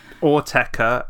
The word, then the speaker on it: Autechre: Am I saying Autechre right? I know some people have different ways, but you're from the UK, so how do you say it? – Autechre. And I Autechre.